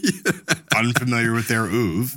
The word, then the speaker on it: oeuvre: I know that the correct pronunciation is oeuvre.